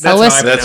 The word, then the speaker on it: Elwes: Elwes, yeah.